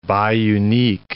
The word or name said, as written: biunique